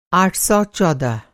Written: आठ सौ चौदह

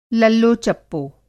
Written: लल्लो-चप्पो

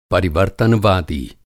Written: परिवर्तनवादी

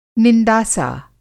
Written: निंदासा

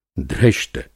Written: धृष्ट

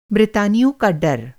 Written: ब्रितानी

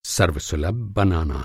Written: सर्वसुलभ

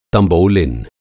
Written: तँबोलिन

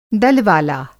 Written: दल-वाला

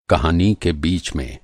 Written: बीच

